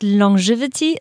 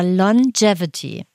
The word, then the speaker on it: Longevity: Ich glaube, wir haben immer gesagt Longevity, es heißt aber Longevity.